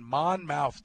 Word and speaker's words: Monmouth: They'll take on Monmouth tonight. – Mm-hmm. – That's how it's pronounced.